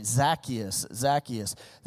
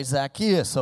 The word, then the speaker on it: Zacchaeus: But if you hear me say his name today, I'm going to call it the old Ozark way, Zacchaeus, okay? And there's multiple ways you can actually pronounce that.